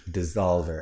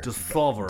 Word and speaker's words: Dssolvr: Dissolver. – And it's spelled D-S-S-O-L-V-R. – Like Revolver. – Dissolver.